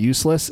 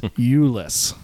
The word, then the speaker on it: Euless: Because my cousin lives in Euless. I have to say it correctly because they call it Useless, and I've gotten used to calling it Useless, but it's Euless.